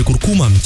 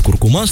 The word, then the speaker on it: куркума: В русском языке изменилась норма ударения в слове «куркума». Теперь основным вариантом считается на последний слог. Об этом сообщил научный сотрудник Института русского языка Владимир Пахомов. Его слова приводит газета.ру. Он заявил, что теперь, согласно большому словарю ударений русского языка, вариант «куркума» стал основным, а прежнее произношение куркума перешло в категорию допустимой старшей нормы.